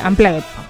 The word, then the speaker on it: Unplugged: ¿Unplugged o Unplugged? ¿Cómo se dice? – Unplugged. Se escribe UP, pero es Unplugged.